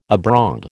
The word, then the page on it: abroad: American English